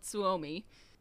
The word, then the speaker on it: Suomi: Now, I added my own aside here, saying, except in Finnish, the country is actually called Suomi. S-U-O-M-I, in case I'm not pronouncing that right.